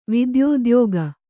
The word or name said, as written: ವಿದ್ಯೋದ್ಯೋಗ